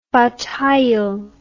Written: bat hayil

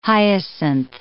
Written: hyacinth

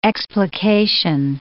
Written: explication

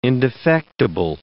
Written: indefectible

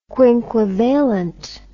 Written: quinquivalent

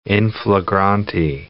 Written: in flagrante

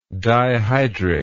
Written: dihydric

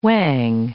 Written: whang

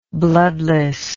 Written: bloodlessness

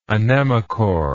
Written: anemochore

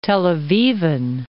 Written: Tel Avivan

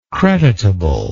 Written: creditable

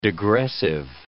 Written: degressive

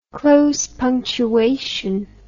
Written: close punctuation

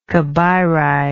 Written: cabeiri

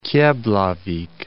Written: Keflavik

